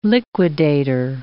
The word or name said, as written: liquidator